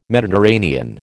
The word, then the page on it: Mediterranean: American English